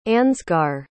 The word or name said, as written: ansgar